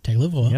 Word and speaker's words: Tagovailoa: You can say it right. – Tagovailoa. Yep.